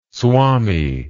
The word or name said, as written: swamy